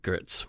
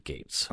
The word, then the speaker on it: Goetz: This time, it was another investigator, Detective William Gates, asking the questions, and Gates is spelled G-O-E-T-Z. – That's interesting. – Some people might pronounce it Gertz, but he pronounced it Gates.